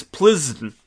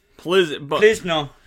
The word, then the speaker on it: Plzeň: It's Plzeň. Plzeň. It's a name of the Bohemian city of Plzeň, also known as Pilsen.